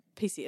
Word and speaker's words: Pici: PC. It's PICI, but it's apparently pronounced PC.